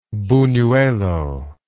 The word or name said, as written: bunuelo